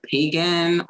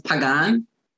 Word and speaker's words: Pagan: They call me either Pagan or... – Oh, yeah, because it's spelled that way. – Yeah, it's spelled that way, but it's I'm Puerto Rican, so it's Pagan.